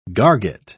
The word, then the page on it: garget: British English